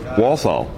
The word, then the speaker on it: Walthall: Walthall. – Walthall. – Mm-hmm. – How do you spell that? – W-A-L-T-H-A-L-L.